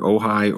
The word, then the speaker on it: Ojai: Is it Ojai, California? – That's right. Yeah, that's always the first question in each podcast is how to pronounce Ojai, and you got it right.